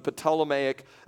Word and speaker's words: Ptolemaic: That was the king, the Ptolemaic dynasty. That's how it's pronounced.